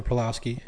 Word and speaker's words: Pawlowski: And I look at what's happening right now, and we were talking about a lot this week this pastor in Canada, pa- Pawlowski, is that how you say his name? – Yeah, Artur Pawlowski.